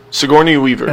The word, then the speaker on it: Sigourney Weaver: Sigourney Weaver? Is that how you say it? – Sigourney Weaver. – That's it.